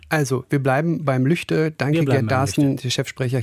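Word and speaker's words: Lügde: Denn viele Menschen, die nicht im Nordrhein-Westfälischen oder im Westfälischen oder eigentlich müsste man sagen im Lippischen leben, denen wird die Aussprache Lüchte angesichts der Schreibweise erstmal eigentümlich vorkommen und halten das vielleicht für falsch.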